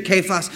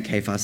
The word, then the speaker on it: Cephas: I keep saying Cephas. That's the way you're supposed to pronounce it, but I were in the South. I should say Cephas, shouldn't I? Okay, Cephas.